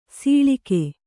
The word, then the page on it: ಸೊಳಗ: Kannada